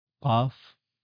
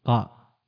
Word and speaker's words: قاف: وكذلك قاف، فنقول سورة قاف باسمها، باسم الحرف، ولا نقول قاء بصوته.